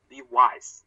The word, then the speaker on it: Levi's: 我有哎，而且就是我要跟大家说一下，就是李维斯，就是它的读音，其实它不是，它不叫 Levis，它叫 Levi's，它它它其实正确的读音是 Levi's，这个希望大家能稍微注意一下。